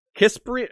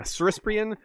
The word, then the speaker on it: Crispian: Lord Crispian, or Kisprian? I don't know, you can't do that one with a hard C, can you? – Crispian? – Yeah, Crispian. Soon, he was the master of coin, or master of soin, if you prefer.